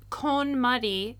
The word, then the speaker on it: KonMari: And when they say KonMari, they're pronouncing it correctly.